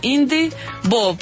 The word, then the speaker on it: Indievo: Es, no, se llama en español Festival de Cine Independiente de Bogotá, solo que se abrevia de esa forma Indivo, porque... – Y se escribe Indievo.